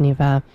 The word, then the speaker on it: Niamh: Spelled Niamh. It's actually pronounced Neva. I did look it up because I was unsure, and it is an old spelling.